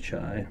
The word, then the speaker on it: qi: chi, of Chinese medicine.